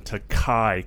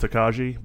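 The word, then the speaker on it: Tokaji: It's a blend of straight rye whiskey finished in Takai cask. If you were to look at it, it looks like it would be pronounced Takaji, but it's a Hungarian wine cask, and this is an acquaintance of mine's brand, and it is phenomenal.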